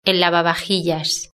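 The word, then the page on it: el lavavajillas: European Spanish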